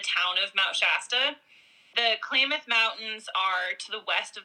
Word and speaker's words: Klamath: Yeah, Klamath.